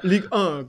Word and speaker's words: Ligue 1: Ligue 1, genau.